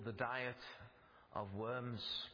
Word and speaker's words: Worms: And it was in the German city of Worms, which in English is pronounced Worms.